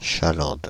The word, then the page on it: chalande: Paris